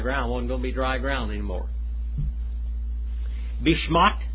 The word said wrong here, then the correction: Bishnat: Bishnat. I'll get it right in a minute. Bishnat. That's how you say it.